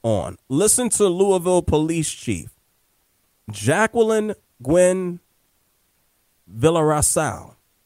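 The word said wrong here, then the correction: Villaroel: Hickey, you know, Villa, how do you say that? You know? – Villa Royale. – Royale. – Villa Royale. – Oh, that's an O, not a C.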